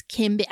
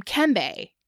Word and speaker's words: Keembert: And so it was spelled K-E-E-M-B-E-R-T. And correct French pronunciation of his name is Kimber. – Oh. – Right? – Yeah. Yeah. Yeah. – But that's not a Creole pronunciation. So no one called him Kimber. – Yeah. – They called him Kembe in the orphanage. – I see. – Because that's more of the Creole pronunciation.